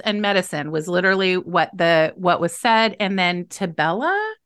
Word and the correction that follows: Tibolone: I'm not sure if I'm saying that right. – Tibolone. Yeah.